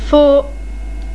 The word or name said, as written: Faux